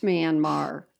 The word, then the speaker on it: Myanmar: Well, really, who is talking to Myanmar and being like, how many feet is your car length?